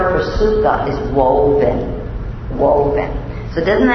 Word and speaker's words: sukkah: But the Jewish people were told to build a sukkah. Am I saying that right, Valerie? Sukkah. Okay. Sukkah.